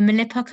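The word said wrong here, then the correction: Mappila: community in Kerala. Is that correct? – Mapla. – Mapla. Sorry. I knew Mapla.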